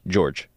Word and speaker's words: Jorge: Seu, Seu Jorge. George spelled as J-O-G. J-O-R-G-E.